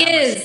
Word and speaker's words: Amerson: It Amerson? – Is.